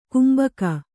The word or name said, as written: ಕಂಬಿಗ